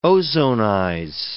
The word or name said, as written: ozonize